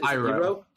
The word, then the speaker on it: Iroh: So next up, best TV character, we have Saul Goodman, Iro. Is that how I pronounce it? – Iroh.